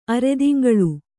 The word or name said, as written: ಅರೆದಿಂಗಳು